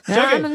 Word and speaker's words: Shaggy: You're saying it wrong, Brayden. It's Shaggy.